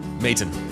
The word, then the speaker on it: Maton: Have we been saying it wrong? – Yeah. – Shit. How'd you find that out? – Researching them. Maton.